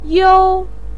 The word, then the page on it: you: 1